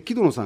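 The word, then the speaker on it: 城殿: はい、で、木戸野さんが、城と殿と書いて木戸野さんで、岡崎なんですよね。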